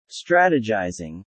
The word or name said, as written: strategizing